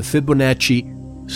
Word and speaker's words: Fibonacci: the Fibonacci sequence, which rhymes with Bonacci.